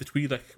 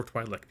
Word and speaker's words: Twi'lek: Yeah, Ryloth, the home of the Twi'lek or Twi'lek people, both of which are correct pronunciations, I've heard.